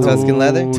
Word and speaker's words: Tuscan Leather: I don't want to pronounce that wrong. Tuscan Leather? – Tuscan, yeah.